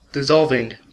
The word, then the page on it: dissolving: American English